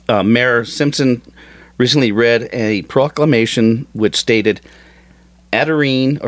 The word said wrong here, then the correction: Aberdeen: or how do you say that? Otterdene. – Is it Aberdeen? – Aberdeen. I'm sorry, God.